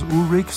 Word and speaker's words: Ulrich: Und da wissen wir, im Moment, da gibt's ja Lars Ulrich, so wird er ja ausgesprochen, nicht Lars Ulrich, den Drummer von Metallica.